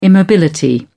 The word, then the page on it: immobility: British English